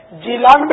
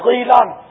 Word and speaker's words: جیلان: اچھا, پھر دوسری کرامت یہ ظاہر ہوئی کہ آپ گھبرا کے جب چھت پر چڑھے اپنے گھر کی تو یہ قسم جیلان, اس کی دو قراءتیں ہیں, دو تلفظ ہیں, جیلان بھی اس کا تلفظ ہے, جیلان بھی غین سے غیلان بھی اس کا تلفظ ہے.